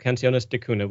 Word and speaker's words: Canciones de Cuna: And so I want to, well, first, I want to tell everybody you've got this album out called Canciones de Cuna, which I'm not great at pronouncing, but I think it just means lullabies, basically.